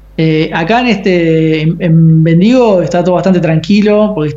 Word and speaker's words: Bendigo: Hablando de house Eating, ahora están en Bendigo, lo voy a decir en castellano, no sé si se pronuncia así. Eso es Nosotros le decimos Bendigo también. Ah, perfecto.